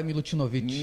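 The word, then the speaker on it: Milutinović: O Bora Milutinovic.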